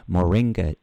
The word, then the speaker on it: Moringa: it's Moringa tea.